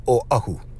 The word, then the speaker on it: Oahu: And the correct pronunciation is Oahu.